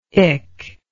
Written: ich

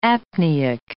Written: apneic

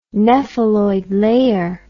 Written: nepheloid layer

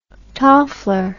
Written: toffler

